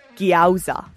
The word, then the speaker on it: gyoza: G-O-Y-Z-A, isn't it? – Mate, it's gyoza. – Gyoza. – That's how you got it. Like, yowza.